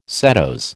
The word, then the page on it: setose: American English